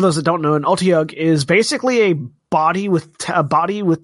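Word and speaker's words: otyugh: Standing at the bottom of the cistern, Tiberius and Kashal and Keyleth stare down a giant Otiug. How do you say it again? – You said it right.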